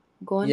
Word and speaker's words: Goenka: For those who are not familiar with Vipassana meditation, introduction of Vipassana by Mr. Gonenka, I don't know how to say his name. Do you know who this guy is? G-O-E-N-K-A. How to pronounce that? – Gonka? – Yeah.